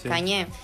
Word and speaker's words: Kanye: Kanye. – Kanye.